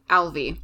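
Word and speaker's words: Ailbe: It's A-I-L-B-E. – Yeah, that makes sense. – Yes, so Alvi.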